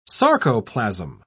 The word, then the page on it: sarcoplasm: British English